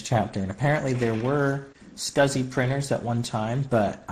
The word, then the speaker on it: SCSI: SCSI, Small Computer Systems Interface, SCSI, which is spelled SCSI, by the way, if you didn't know, but it's pronounced SCSI, is a type of interface that uses parallel communication technology to achieve high data transfer rates.